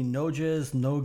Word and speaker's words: Noghes: The very first Monaco Grand Prix was organized by Anthony Noges, I don't know what how this name is pronounced, N-O-G-H-E-S, in 1929. Can you shed some light on the last name, please? N-O-G-H-E-S? – I think you just did fine. Yes, you think so? – Absolutely.